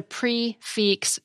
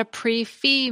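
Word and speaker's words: prix fixe: So a complete meal at a restaurant that's served at a fixed price is a prix-fix meal, because pre is P-R-I-X, while fix is spelled F-I-X-E. It's not a pre-fee meal, as I've heard some servers call it.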